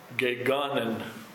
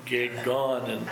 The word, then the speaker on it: gegonen: But in the Greek, it's gegonen. Is how you say it. Gegonen. – How do you spell it? – G-E-G-O-N-E-N. Gegonen.